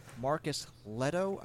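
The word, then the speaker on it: Marcus Lehto: Halo co-creator Marcus Leto, I believe, is how you say that name. – Yep.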